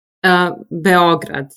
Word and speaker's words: Beograd: How do you say it? Serbia? – Beograd, yes. But Belgrade, yes.